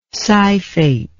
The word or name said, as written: scyphate